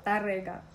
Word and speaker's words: Tárrega: Right, and you made me one called Certified Organic, and you opened my eyes to Estrella Morente, Cesare Alvora, and Francisco Tárrega. I don't know. Is that how you say his name? – Tárrega.